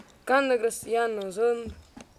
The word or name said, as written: quanti